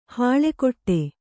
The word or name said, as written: ಹಲ್ಕಡಿ